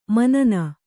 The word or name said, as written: ಮುನ್ನಿ